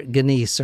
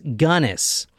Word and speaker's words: Gunness: I might call her Guinness or Guinness or something. – Guinness. – But we are drinking stout. – Yeah. – Apparently it's pronounced Gunness.